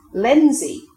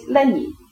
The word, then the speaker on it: Lenzie: Lenzie, now pronounced as written, but previously Lenny, a village near Glasgow.